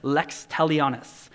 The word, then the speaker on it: Lex Talionis: So, Lex Talionis.